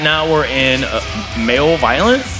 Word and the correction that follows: Malevolence: I'm saying this wrong. – What's it called? – Male violence. Malevolence.